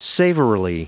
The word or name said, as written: savorily